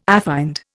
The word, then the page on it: affined: Indian